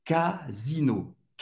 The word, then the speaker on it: Casino: Casino. Casino. O S tem o som do Z. Casino casino, supermarché.